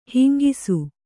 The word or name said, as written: ಹೆಂಗೂಸು